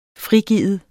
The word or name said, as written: frigive